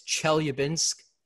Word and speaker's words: Chelyabinsk: I think it's Chelyabinsk. That's how you pronounce the name.